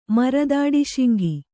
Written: ಮುರದಾಡಿಶಿಂಗಿ

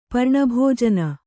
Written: ಪರ್ಣಭೋಜನ

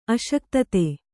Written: ಅಶಕ್ತತೆ

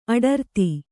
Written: ಅಡರ್ತಿ

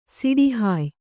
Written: ಸಿಡಿಹಾಯ್

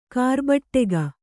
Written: ಕಾರ್ಬಟ್ಟೆಗ

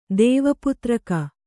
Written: ದೇವಪುತ್ರಕ

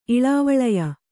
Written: ಇಳಾವಳಯ

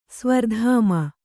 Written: ಸ್ವರ್ಧಾಮ

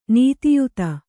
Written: ನೀತಿಯುತ

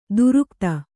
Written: ದೀರ್ಘತೆ

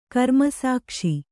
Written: ಕರ್ಮಸಾಕ್ಷಿ

